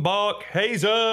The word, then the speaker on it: Barkhuizen: Please welcome to the podcast to be interviewed by our droid, Tom Barkhuizen.